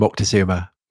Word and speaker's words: Moctezuma: I would call him Moctezuma. – Would you? You're so much woker than I am, Tom. – Is that woker? – I think it's more Spanish. – It's a Spanish pronunciation, isn't it? – Yeah.